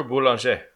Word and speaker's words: Boulanger: And I'm going to attempt to do this in my butchered version of French. A man named Monsieur Boulanger. – Monsieur Boulanger. – Yeah, there you go.